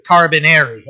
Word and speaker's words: Carabinieri: I have to say it like that, Carabinieri.